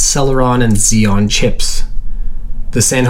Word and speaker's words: Celeron: Celeron is 7 letters and 3 syllables like Pentium. The cell of Celeron rhymes with tell of Intel.